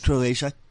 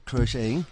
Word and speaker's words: crocheting: Not Croatia, crocheting.